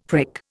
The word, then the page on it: prick: Indian